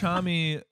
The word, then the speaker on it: Homme: Them Crooked Vultures is a super group made up of Dave Grohl, John Paul Jones, and Josh Holm. – Right, John Paul Jones. – Josh Homme. – Is that how you say it? – I think it's Homme, yeah.